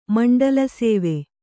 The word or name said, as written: ಮಂಡಲಸೇವೆ